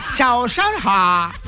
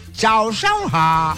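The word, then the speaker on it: Zao shang hao (早上好): Zao shang hao. Hay que colocarle así el tono, ¿no? El acento. Chao, chao, ha.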